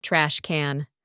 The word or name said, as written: trash can